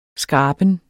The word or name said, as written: skraben